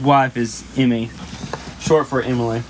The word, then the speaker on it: Emily: No, three syllables. Emily. – Yeah, but... – And then Emmy is two. – But we're here in Arkansas. – Emily.